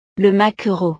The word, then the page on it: le maquereau: frances